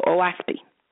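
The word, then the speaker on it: Oahspe: But this is one of the subjects that I speak about, and it's spelled O-A-H-S-P-E. O-A-H-S-P-O, OASPI.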